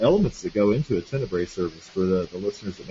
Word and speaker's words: Tenebrae: Is that Tenebrae? Is that how we pronounce that? – Yeah.